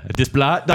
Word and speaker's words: Desplat: Desplat. I'm sure I said it right.